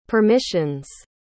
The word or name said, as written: permissions